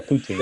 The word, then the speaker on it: poutine: Did I say that right? Poutine? – You say poutine on here. So yeah, poutine. Yeah.